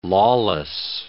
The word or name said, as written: lawless